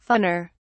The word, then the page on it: fawner: British English